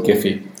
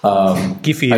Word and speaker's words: Giphy: You pronounce graphical with a G, a hard G. So let's call it Giphy. – Giphy.